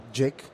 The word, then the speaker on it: J1CK: The name of the company, we used to pronounce it JIC. It's written J1CK, and it comes from just one click.